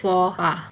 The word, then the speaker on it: faux pas: F-A-U-X space P-A-S. So F-A-U-X is pronounced as faux and P-A-S is pronounced as pas. So faux pas.